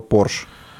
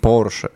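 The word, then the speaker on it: Porsche: Да вот ищите, блядь, Е40, а, ебаный, а, До Стаса же также доебались, он когда себе купил Кайман, он сказал Порш. И ему там все Порше, Порше, правильно говорить, Порше.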